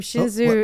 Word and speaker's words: Hsinchu: And we went, we started off in her hometown of, I think it's called Hsinchu. We went from Hsinchu...